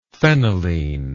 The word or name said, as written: phenylene